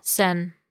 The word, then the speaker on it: Seine: Seine in Paris, Seine River. – Seine.